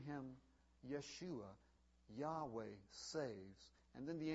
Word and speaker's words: Yeshua: He took the divine name Yahweh and combined it with the boy's name Hoshea, and it's pronounced Yeshua.